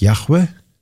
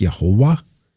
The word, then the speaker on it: Яхве: А вот это имя, которое читается либо как Яхве, либо как Яхова, какое имя, под каким именем мы знаем его сегодня?